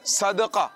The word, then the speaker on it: صدقہ: صدقہ، سواد اور دال کے اوپر زبر ہے، دال ساکن نہیں ہے، صدقہ، آپ صدقہ پڑھ رہے تھے نا، صدقہ نہیں بلکہ دال کے اوپر زبر ہے، صدقہ، صدقے کے فضائل، اللہ کی راہ میں صدقہ دینا، صدقہ دینا، صدقہ دینا نہیں، ٹھیک ہے؟